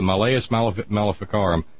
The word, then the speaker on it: Malleus Maleficarum: I could not remember for the life of me how to pronounce it, but it's the Malleus Maleficarum.